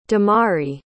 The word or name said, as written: damari